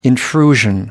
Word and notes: intrusion: American English

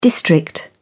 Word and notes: district: British English

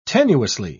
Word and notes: tenuously: British English

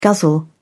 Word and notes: guzzle: British English

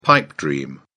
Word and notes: pipe dream: British English

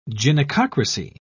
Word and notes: gynecocracy: British English

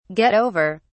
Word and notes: get over: British English

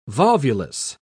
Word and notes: volvulus: British English

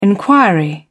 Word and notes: inquiry: British English

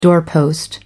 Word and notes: doorpost: American English

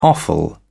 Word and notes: offal: British English

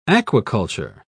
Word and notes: aquiculture: British English

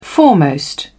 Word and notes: foremost: British English

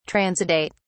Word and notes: transudate: British English

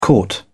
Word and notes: caught: British English

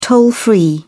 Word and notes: toll-free: British English

